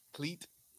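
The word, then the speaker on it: Klete: How do you say his first name? – Cleet. – I think it's Cleet Keller. – Yeah, Cleet Keller. Yeah. – His name is Cleet, as in Cletus.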